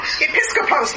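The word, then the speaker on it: Episkopos (ἐπίσκοπος): Episkopos? – Episkopos, like episcopal.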